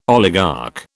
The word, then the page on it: oligarch: British English